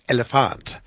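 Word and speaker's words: Elliphant: And he said, well, it's a young rocker rapper from Sweden, and her name is Elephant, and that's how you're supposed to pronounce it. It's Elephant.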